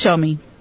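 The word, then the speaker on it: Saumi: Yes, Shomi.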